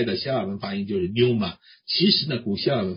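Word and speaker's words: Pneuma (πνεῦμα): 它叫 Pneuma，就是这个派啊叫坡啊派，这个也发音的啊，这个也发音的，在英文当中有一些这方面的词啊尤其是辅音它不发音的，但是呢在希腊文这种辅音也发音叫做 pneuma，它有一个短促的 pneuma 这样发音。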